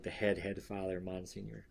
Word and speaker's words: Monsignor: Remember in the beginning of the book where they're like, hey, sorry. Monsignor. Senior, not senior. That's how it's pronounced.